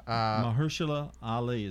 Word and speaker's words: Mahershala Ali: Mahershala Ali, I think is how you say it. – Oh, yeah. – Mahershala Ali.